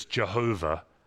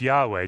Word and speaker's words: Yahweh: Some of you have heard it's Jehovah. No, it's not. It's Yahweh. Jehovah is a mispronunciation of what it really is.